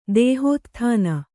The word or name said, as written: ದೇಹೋತ್ಥಾನ